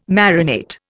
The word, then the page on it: marinate: Indian